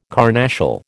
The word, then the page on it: carnassial: American English